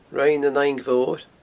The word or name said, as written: Rinn an Fhaing Mhoir